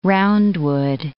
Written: roundwood

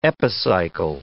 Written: epicycle

